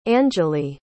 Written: angele

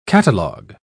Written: catalog

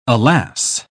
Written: alas

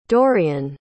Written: Dorien

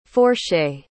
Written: fourchée

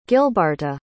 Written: gilbarta

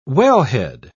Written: wellhead

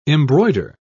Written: embroider

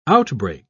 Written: outbreak

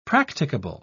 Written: practicable

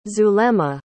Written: zulema

